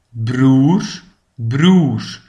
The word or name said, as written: de broer